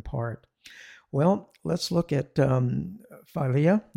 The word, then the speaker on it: philia: Yeah, philia, philia. I did look up some Greek pronunciation guides, and I work with some people that speak Greek, and I checked with them, and I think philia is acceptable.